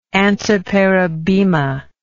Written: antiparabema

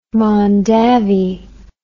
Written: mondavi

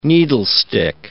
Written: needlestick

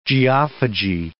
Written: geophagy

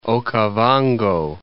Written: Okavango

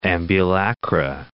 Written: ambulacra

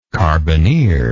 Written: carbineer

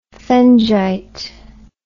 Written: phengite

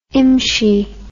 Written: imshi

